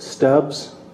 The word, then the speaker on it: Stubbs: David Stubbs. Last name, S-T-U-B-B-S.